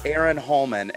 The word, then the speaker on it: Arron Holman: Do you ever think that your name sounds like a-hole human, like asshole human? – 100%. That is intentional, and I'll tell what. Oh, that's 100% intentional. Because, listeners, my name is Aaron Holman, and it's spelled A-R-R-O-N-H-O-L-M-A-N.